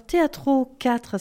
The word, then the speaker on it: Théâtro: Alors Théâtro 4, c'est bien comme ça qu'on prononce? – Oui.